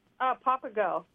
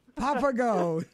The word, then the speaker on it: Papago: Papago. Papago, yeah.